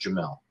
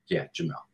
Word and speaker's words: Yamil: And also, without the Spanish accent, my name is pronounced Jamil. So, Jamil. Just wanted to say for the English speaking audience.